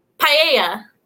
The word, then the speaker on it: paella: P-A-E-L-L-A? – Paella. – Is that how it's pronounced? – Paella. Paella. No, I would not guess that spelling for that word.